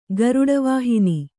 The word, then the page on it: ಗರುಡವಾಹನ: garuḍa vāhana